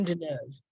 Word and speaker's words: Deneuve: Catherine Deneuve.